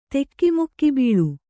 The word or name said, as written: ತೆಕಿಮುಕ್ಕಿಬೀಳು